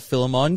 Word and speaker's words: Philemon: It was to Philemon who Paul wrote this in the book of Philemon. Similar pronunciation to filet mignon as we were giving people clues in that one there.